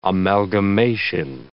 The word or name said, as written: amalgamation